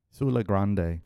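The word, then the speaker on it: Siula Grande: Sulla Grande.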